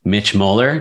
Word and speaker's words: Mitch Moeller: With me today, I have Mitch Moeller.